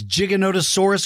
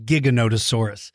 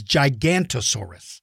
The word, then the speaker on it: Giganotosaurus: But here is the confusion. When you look at the name, you think it says Gigantosaurus. And so did a lot of people in the media. That means from the radio, newspaper, and television. They mispronounced it. They called it Gigantosaurus.